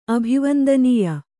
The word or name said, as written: ಅಭಿವಂದನೀಯ